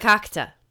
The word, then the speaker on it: verkakte: It is actually spelled V-E-R-K-A-K-T-E. – Can I see that? Can I look at that? Vakakta. – Vakakta. Vakakta. – But so, don't pronounce the R.